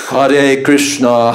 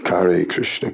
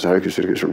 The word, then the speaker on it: Hare Krishna: Hare Krishna, Hare Krishna, Krishna, Krishna. – Yeah. Oh God, and then all the terrible pronunciations. Oh. Ay, ay, ay. Kare Krishna, Kare Krishna.